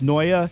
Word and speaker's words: Noe: One of them is, her name's pronounced Noia, but it's spelled N-O-E. And you can find her on Instagram, N-O-E, La Chica Del Topless.